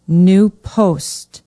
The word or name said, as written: new post